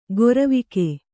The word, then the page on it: ಗೊರವಿಕೆ: goravike